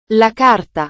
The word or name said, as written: la carta